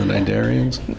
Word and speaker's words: cnidarians: The cnidarians? – That word comes from the word nidi. It starts with a C, but you don't pronounce it. It's a C-N, right? – Uh-huh.